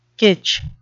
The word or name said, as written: kitsch